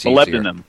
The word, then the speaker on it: molybdenum: It's molybdenum.